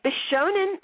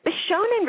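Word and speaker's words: bishōnen: Bishonen, it's spelled B-I-S-H-O with a line over it, N-E-N. Bishonen refers to males with distinctly feminine features, physical characteristics that represent the standard of beauty in Japan.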